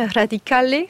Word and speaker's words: Radicale: Vous êtes vous écrivain et correspondant de la radio italienne Radicale, c'est ainsi qu'on prononce ? – Parfaitement.